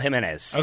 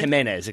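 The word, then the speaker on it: Jiménez: So I've gone with up front, Raul Jimenez. – Okay. – Jimenez, excuse me. Got to learn how to pronounce it perfectly.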